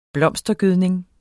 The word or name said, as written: blomstergødning